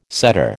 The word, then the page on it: setter: American English